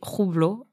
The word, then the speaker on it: Hublot: Et il lui propose une montre de la marque Hublot, prononcée Roublot en russe.